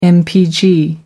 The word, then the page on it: mpg: American English